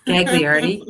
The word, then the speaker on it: Gagliardi: Did I say that right? – Gagliardi. Yeah.